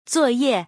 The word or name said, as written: zuo4ye4